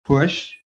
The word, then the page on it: PUSH: American English